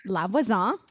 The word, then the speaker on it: La Voisin: And am I saying that correctly? – La Voisin. Right.